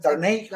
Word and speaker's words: Darnay: Va, van, a, van a jugar también Darnell Holmes, ¿cómo se llama? Darnay, Holmes.